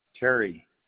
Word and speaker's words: Terry: Terry, T-E-R-R-Y. – Thank you. That's T-E-R-R-Y? – Yes.